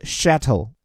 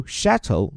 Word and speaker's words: chateau: 然后后面这个词 chateau 听着就很法语嘛，有没有？它其实本来指的就是法国旧时代的那些个城堡或者是庄园。 So chateau means a castle or large country house in France. 所以这个词的发音，各位着重可以在我们每天的免费晨读营里面听到我们纠音师的讲解。我这里简单讲一点，就是法语里面很多时候这个 ch 啊发的是 sh，而不是英文当中的 ch，然后后面这个 eau 发的是 o 的音，所以很有气质的把这个词念出来叫 chateau。